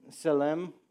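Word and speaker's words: tselem: And I love that, you know, in that passage when it says that we bear the image and the likeness of God, the word for image is, I'm going to probably butcher this, it's Selem, it's T-S-E-L-E-M.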